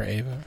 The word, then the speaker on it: Eva: Is it Eva or Ava? I think they were... It's spelled Eva, but they were calling her Ava.